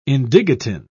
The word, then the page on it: indigotin: British English